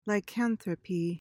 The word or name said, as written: lycanthropy